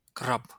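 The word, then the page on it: краб: Belarusian; krab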